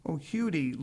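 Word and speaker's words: Huddie: The chapter on Leadbelly or Huddle, pronounced Hudie Leadbetter, tells a tangled story that is not easily summarized.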